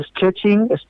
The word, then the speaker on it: Szczecin: Y la primera ciudad es una de nombre impronunciable, yo digo Szczecin, más o menos esa es la pronunciación. Para que os hagáis una idea, empieza por SZCZ.